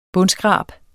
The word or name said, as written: bundskrab